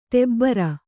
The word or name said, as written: ಟಬರು